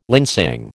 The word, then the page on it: linsang: American English